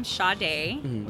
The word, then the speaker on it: Sade: It is my first name, Sade, which is spelled like S-A-D-E. I was named after the jazz singer. – Mm-hmm. – So it's Sade, period, I-M-G.